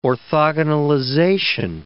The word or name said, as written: orthogonalization